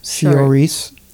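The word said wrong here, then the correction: Saoirse: Charcy, that's how you say her name.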